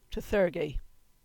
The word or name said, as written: Trethurgy